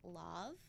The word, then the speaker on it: Lauv: So, I think you pronounce his name like Love. L-A-U-V. – Love.